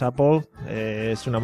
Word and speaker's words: Apple: Vino Alfalta 90 y nos dijo: Es Apple. Aquí en, América decimos Apple, así que Apple.